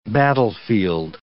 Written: battlefield

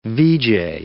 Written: VJ

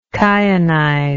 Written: kyanize

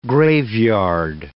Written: graveyard

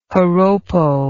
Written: joropo

